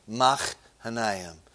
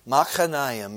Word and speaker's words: Machanaim: That's exactly how it's pronounced. It's the little ch in the back of your throat. Machanaim. But here's what's unique about it. Machanaim means two hosts, two armies.